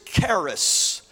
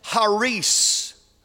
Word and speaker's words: charis: The Greek word, if you go back and look at the Greek word for grace, the Greek word is charis. That's how we pronounce it in our English language, is charis. But actually, it's pronounced haris.